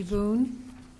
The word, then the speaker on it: Boone: Oh, my name is Sandy Boone. – Can you spell that? – Is it B-O-O-N-E. – Thank you. – Like Daniel.